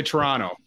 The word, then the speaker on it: Toronto: We don't pronounce the last T really. – Toronto? – So we say Toronto. – So like there's a Pirano? – Kind of. – Yeah. – Yeah. For some reason, Canadians do not pronounce the second T.